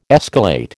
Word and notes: escalate: American English

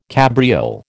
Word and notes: cabriole: American English

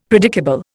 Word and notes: predicable: Indian

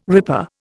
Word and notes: ripper: Indian

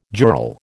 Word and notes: jural: American English

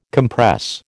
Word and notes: compress: American English

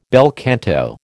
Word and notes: bel canto: American English

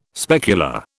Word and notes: specular: British English

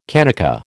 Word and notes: kanaka: American English

